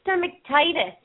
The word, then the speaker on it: stomatitis: Long hair, white patch on his chest, was diagnosed with, I don't even know how to say this, stomatitis and eye herpes.